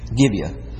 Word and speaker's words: Gibeah: Gibeah.